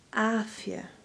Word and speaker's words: Afia: Well, it's actually quite simple because it's just said Afia. The only thing that really makes it hard is the long ah at the beginning.